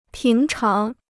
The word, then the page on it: 平常: píng cháng